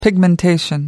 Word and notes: pigmentation: American English

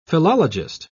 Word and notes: philologist: British English

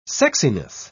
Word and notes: sexiness: British English

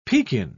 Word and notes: pekin: British English

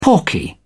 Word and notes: porky: British English

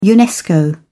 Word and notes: unesco: British English

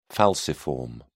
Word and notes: falciform: British English

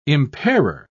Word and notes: impairer: British English

